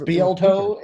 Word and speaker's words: Spieltoe: Spielty. – What? Wait, what? – Yeah, that weird what? Name at the beginning. – Spieltoe. It's spelled Spieltoe. S-P-I-E-L-T-O-E.